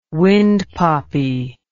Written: wind poppy

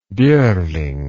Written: bjoerling

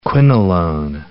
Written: quinolone

